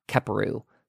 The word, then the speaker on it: Kheperu: And that's spelled K-H-E-P-E-R-U, Keperu.